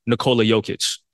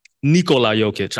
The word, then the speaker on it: Nikola Jokic: We've seen Rui Hachimura guard Nikola Jokic at times. Or I'm sorry, Nikola Jokic. I said his name wrong.